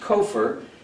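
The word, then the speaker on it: kofer: The same letters, kafar is pronounced kofar, which means the price of a life or ransom.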